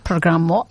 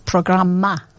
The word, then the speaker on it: programma: Marco, he said it's not programmo, it's programma.